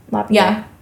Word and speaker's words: LaPierre: LaPierre, is that how you say his name? LaPierre. – Yeah.